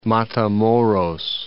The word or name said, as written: Matamoros